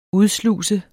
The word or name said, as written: udsluse